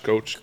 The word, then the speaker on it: Koch: Koch. K-O-C-H.